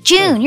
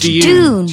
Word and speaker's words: Dune: You are both pronouncing that incorrectly. June, you're saying June. – June. – That's J-U-N-E you're saying. – No, you're right, actually. Hang on. – It's pronounced Dune. – June. – Like D-O-O-N. Dune.